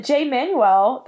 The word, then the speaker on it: Jay Manuel: Jay Manuel, that's how you say his name, right? – Yeah.